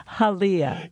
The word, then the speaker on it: Halia: Well, I mispronounced the name of your company when we first met. I said Halia, H-A-L-I-A, and I was like, no, it's Halia, which is Hawaiian.